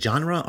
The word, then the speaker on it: genre: Is it genre or genre? – Genre. I think it's a soft G.